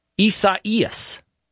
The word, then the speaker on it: Isaias: The name of this hurricane is Hurricane, I gotta look up the pronunciation here, Isaias. What? Isaias. What? Okay, so apparently Isaias is the Spanish and Portuguese form of the biblical name Isaiah.